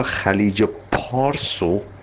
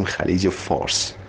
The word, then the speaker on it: خلیج پارس: اونی که پرشین گلفو میگه خلیج فارس، باید بدونه چقدر زبان عربی توی ما ریشه دوونده که حتی خلیج پارسو که با پی تلفظ میشیم ما میگیم خلیج فارس، به خاطر اینکه عربا ف نداشتن خلیج پارس رو میگن خلیج فارس.